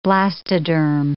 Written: blastoderm

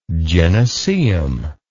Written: gynaeceum

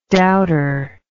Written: douter

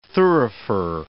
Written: thurifer

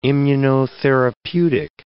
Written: immunotherapeutic

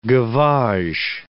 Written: gavage

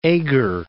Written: Eger

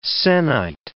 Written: se'nnight

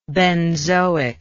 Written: benzoic

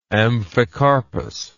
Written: amphicarpous

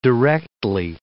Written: directly

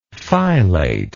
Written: filate

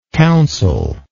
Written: counselable

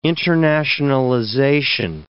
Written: internationalization

